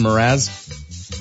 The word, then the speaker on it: Mraz: Mraz.